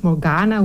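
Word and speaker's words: Morgane: Dus ze heeft het naar zijn vrouw Morgaine of Morgana. Hoe spreek je het uit? – Morgan. – Ja, maar omdat er een E achter staat. – En die slik je in.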